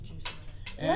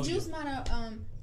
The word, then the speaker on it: Yusmara: And you can follow me on Instagram at I am the Juice Mata. – The Juice Mata. And always. – The Juice Mata. Juice Mata, y'all. That is how you say my name. Y-U-S-M-A-R-A.